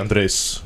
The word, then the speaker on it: Andrés: André du Bouchet is with me. – Hello. – I said it correctly, did I not? – Oh, you said it absolutely right. – Some people do pronounce the S.